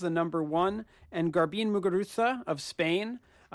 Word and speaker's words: Muguruza: It's spelled with a Z. I always like that. They say Muguruza because it's kind of got that lisp, that the Barcelona lisp kind of thing they do.